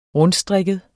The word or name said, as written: rundstrikket